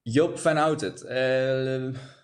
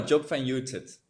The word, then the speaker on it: Job van Oudert: Did I say your name correctly, Job? – Job van Oudert. Depends. – Outed. Well, okay, there we go. – No, Job van Oudert. But that's fine for me if you pronounce it like that.